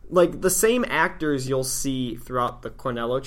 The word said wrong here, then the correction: Cornetto: Cornetto? – It's Cornetto Trilogy. – Cornetto. – Wow, I suck.